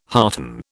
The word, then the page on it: hearten: British English